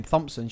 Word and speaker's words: Thompson: But like, if I didn't know my name was Thompson with a H, I wouldn't write it with a H. I'd write T-O.